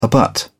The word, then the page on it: abut: British English